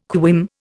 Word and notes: quim: Indian

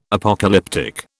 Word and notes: Apocalyptic: British English

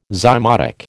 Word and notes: zymotic: American English

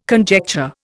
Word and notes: conjecture: Indian

